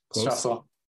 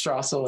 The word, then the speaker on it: Trassel: Trassel. – Trussel? Like, it's kind of a... – Just Trassel, like Straws.